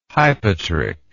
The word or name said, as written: hypotrich